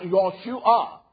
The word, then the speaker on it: Yahshua: You saw that in Scripture whereby the name salvation is pronounced, Yahshua. And that is His name. No, it's not something I can conk. It is just a true enunciation, the Hebrew enunciation.